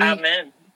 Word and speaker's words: Amin: Amin. – That New York amen, right? – Mm-hmm. – It's like always say the long A, not the short A, the long A. – Like, you know, hallelujah, amen.